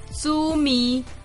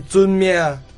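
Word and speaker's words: 遵命: 猪咪？ – 不是猪咪，是尊命。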